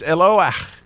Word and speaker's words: Eloah: It's pronounced Eloah, which is essentially very similar to the El name we talked about, which is an Aleph and a Lamed.